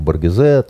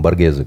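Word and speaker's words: Боргезе: Эта книга посвящена диверсанту э, итальянскому Второй мировой войны, знаменитому Э, можно по-разному произносить его фамилию, на французский манер Баргезе, там, а итальянцы все-таки, по-моему, Баргезе говорят, да.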